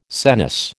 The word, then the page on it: senesce: American English